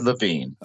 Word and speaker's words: Lewin: Well, actually, if you were in Germany, you might have said Levine.